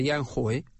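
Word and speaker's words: Янь Хуэй: Произносят как хой, хуэй и так далее. Ян хой. Он вот так произносится по-китайски, да?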